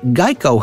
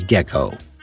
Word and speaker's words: Geico: They chose a gecko because Geico had often been mispronounced as gecko.